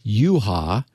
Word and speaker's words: Jucha: This author's name is pronounced Scott Yuha, which is spelled J U C H A.